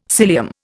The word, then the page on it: cilium: Indian